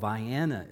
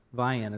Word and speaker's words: Vienna: My brother and I grew up in a small town called Vianna, Illinois. Small town in southern Illinois. It's spelled like Vienna, but don't say it that way down there. Say Vianna. Vianna is the way they say it.